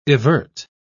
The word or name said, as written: evert